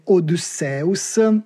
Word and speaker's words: Odysseus: quindi se volessimo trattare questa parola come una parola in greco antico, e quindi rispettando l'accentazione per quanto possibile e la pronuncia originale, dovremmo dire Odysseus.